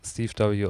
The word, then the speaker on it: STVW: Dass das Steve W.